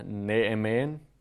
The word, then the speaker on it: Naaman: Jeg vil gerne lige starte med at sige, jeg tror, den rigtige udtale er Naaman. Jeg tror, eller jeg ved, at den apostrof, der er, gør, at man skal adskille det. Det er bare nemmere at sige Naaman. Så I må bære over med mig. Jeg kommer ikke til at udtale hans navn rigtigt. Men det er bare, så I ved, at der er en anden, mere hebraisk måde at udtale det på.